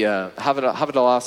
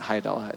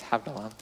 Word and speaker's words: Havdalah: And Rabbi Daniel Lappin talks about the Havdalah service. That's how you say it. It's not Haidalah, it's Havdalah. Service is recited over a cup of wine that runs over into the saucer beneath.